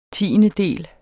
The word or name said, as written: tiendedel